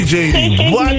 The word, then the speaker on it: Jeanne: Hey, spreek je naam goed uit, Jeanne? – Is het niet Janie? – Janie. – Ik dacht al Janie. – Ik was zomaar Janie van. Sorry Janie.